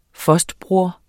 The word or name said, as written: fostbroder